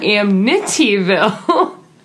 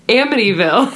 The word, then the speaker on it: Amityville: No, not Amityville. It's Amityville.